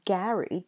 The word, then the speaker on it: Gary: Whereas the boy's name, Gary, G-A-R-Y, that rhymes with Harry, as in Harry Potter, or Prince Harry. And so Mary, the girl's name, has an air vowel sound, and Gary, the boy's name, has an ah sound.